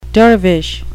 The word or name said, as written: dervish